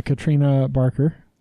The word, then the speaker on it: Katrina Barker: Katrina Barker. I said it right? – Yeah.